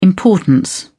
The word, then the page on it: importance: British English